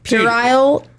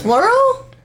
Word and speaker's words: puerile: Puerile. It's P-U-E-R-I-L-E. – It's definitely a stag club, but instead of like... It's definitely... – Is it like plural? – No, it's puerile acts of blasphemy.